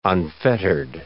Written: unfettered

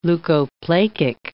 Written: leukoplakic

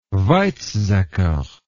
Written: Weizsacker

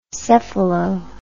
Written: cephalo

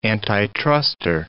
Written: antitruster

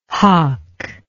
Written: hough

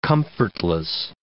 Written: comfortless

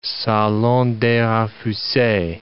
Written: salon des refuses

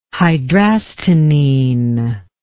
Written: hydrastinine

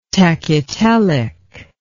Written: tachytelic

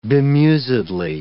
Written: bemusedly

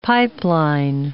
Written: pipeline

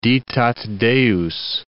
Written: ditat Deus

